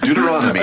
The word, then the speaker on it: Deuteronomy: Deuteronomy.